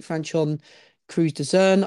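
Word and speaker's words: Franchon Crews-Dezurn: She beat Franchon Cruz de Zern. I think I've said that right. – Yeah. Bang on.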